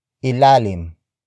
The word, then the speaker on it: ilalim: Nasa, ilalim, ng, kama, si, Elaine.